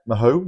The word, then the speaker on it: Mahou: Yep, Mahou, Mahou, Mahou.